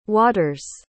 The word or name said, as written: water's